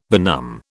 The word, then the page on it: benumb: British English